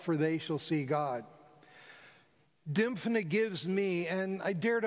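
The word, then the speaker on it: Dymphna: Saint Dimphna, a young Irish girl who was martyred by her own father in the 7th century.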